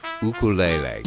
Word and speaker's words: ukulele: When Jake Shimabukuro pronounces the name of his instrument, it's a beautiful murmur floating on a tropical breeze, ukulele. the more common pronunciation lands on the ear with a comical thud.